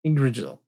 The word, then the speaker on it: Yggdrasil: Dave Mattingly phonetically spelled out Yggdrasil. – Yggdrasil.